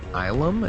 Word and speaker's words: Ylum: And it's the story takes place on the moon Ilum. It's spelled Y-L-U-M, but there's a little editorial box there that says pronounced Ilum.